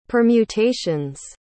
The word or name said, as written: permutations